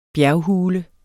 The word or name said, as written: bjerghule